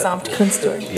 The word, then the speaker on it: Gesamtkunstwerk: Gesamtkunstwerk.